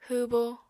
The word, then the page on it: hwwbo: apatani